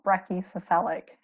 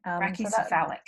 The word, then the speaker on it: brachycephalic: Yeah, so they're called brachycephalic. – Brachycephalic.